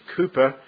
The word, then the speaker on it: Cowper: But the classic example is that of William Cowper, spelled Cowper, but in fact pronounced Cooper, the 18th century Christian poet and hymn writer whose hymn we sang just before.